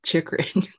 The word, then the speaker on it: Chychrun: Chychrun.